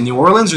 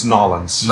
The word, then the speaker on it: New Orleans: Is it New Orleans or New Orleans? – Okay, it's three. It's New Orleans. – Yeah. – It's New Orleans. It's Nolens. – Nolens. Like the colloquial term.